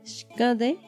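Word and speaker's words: xkäde: Shkade, Shkade es buenas tardes, em, que se escribe con X, la K la A nasal, espacio, la D, la E.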